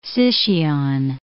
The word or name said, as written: Sicyon